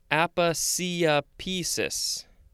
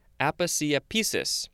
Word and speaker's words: aposiopesis: Aposiopesis. Aposiopesis. A-P-O-S-I-O-P-E-S-I-S. Aposiopesis. Six syllables.